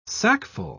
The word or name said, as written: sackful